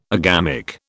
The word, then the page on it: agamic: British English